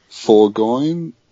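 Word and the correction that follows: Forgione: The name is Forgio.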